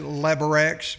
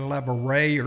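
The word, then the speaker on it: Labyorteaux: I always pronounce it Labarex, but it's actually Labaray or something like that.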